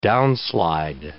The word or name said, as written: downslide